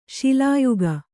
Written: ಶೂಲಯೋಗ

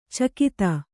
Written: ಚಕೋತ್ತು

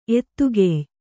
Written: ಎತ್ತುಗೆಯ್

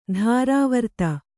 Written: ದುರ್ವೃತ್ತೆ

